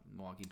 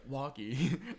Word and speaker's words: Milwaukee: Yeah, Milwaukee. And they say it like just the two syllables. – Milwaukee.